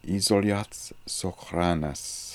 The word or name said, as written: Isoliazsokhlanness